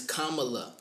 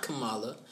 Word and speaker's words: Kamala: Her name is Kamala. – She pronounces Kamala. It Kamala. – Kamala Harris is her name. Kamala is a name that you hear a lot, and I'm like, no, it's not Kamala.